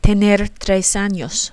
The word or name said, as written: tener tres años